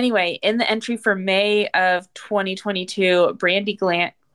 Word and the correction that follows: Glanville: Glanville, did I say his name? Glanville.